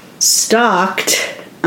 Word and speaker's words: stalked: How would you say that? – Stalked on All Sides. That L throws me every time.